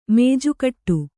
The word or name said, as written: ಮೇಜುಕಟ್ಟು